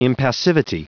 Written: impassivity

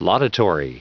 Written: laudatory

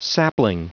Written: sapling

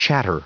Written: chatter

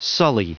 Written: sully

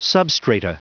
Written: substrata

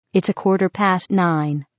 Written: quarter past nine

